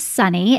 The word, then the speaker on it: Sunny: So first, yes, my real name is Sunny, and it is on my birth certificate like that. It is not a nickname, and it is spelled S-U-N-N-Y, just like the weather.